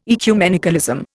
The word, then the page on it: ecumenicalism: Indian